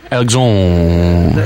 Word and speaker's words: Alexandre: And it's pronounced Alexandre. – It's A L E X A N D R E Tanus. T A N N O U S. – But it just kind of disappears, Alexandre.